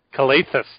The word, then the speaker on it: Calathes: So I knew it was Nick Calathes all along, or however you say his name. Calathes? Calathes. That's what I said, Calathes.